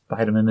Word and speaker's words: Biedemann: I don't know shorthand, but I guess it's phonetic, and there's an account from way back that they discovered just recently, and it says Biedemann is the official pronunciation.